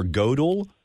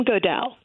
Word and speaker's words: Goodell: But there's one character that starts off towards the beginning of the book, and it's just fascinating, and I'm not sure how to pronounce his name correctly. Is it William Goodell or Godel? – William Goodell.